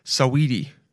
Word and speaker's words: Saweetie: Saweetie? That's how you say it. Saweetie.